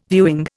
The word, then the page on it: viewing: Indian